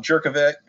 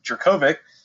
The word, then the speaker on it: Jurkovec: Jurkovec, or excuse me, Jurkovec, as we talked about before. There is a proper pronunciation of that name.